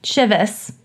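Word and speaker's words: Chivas: How do you say it? – Shivas. – Shivas.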